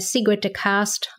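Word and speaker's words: Sigrid Dekaste: I'm on LinkedIn and Facebook, of course, and Sigrid DeCast on my under my name is probably the most straightforward way.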